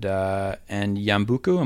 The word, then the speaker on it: Yambuku: So I wonder if you could take us back to 1976 and Yambuku, am I saying that correctly? – I believe so, yes.